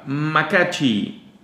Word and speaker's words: macachi: Siguiente palabra, macachi. Cállate. Macachi. Lleva acento en la última I.